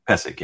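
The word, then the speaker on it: Pecić: i hope I, am pronounced it well but it's bogdan uh pekic i i i, I guess Pecic, yeah. Don Don Don, Don Pecic is how we called him.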